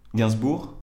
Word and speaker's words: Gainsbourg: Uh, yeah, I, I, I, felt like it was uh, good, except that you you don't pronounce the G at the end. Gainsbourg.